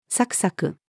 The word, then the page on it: さくさく: female